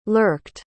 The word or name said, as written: lurked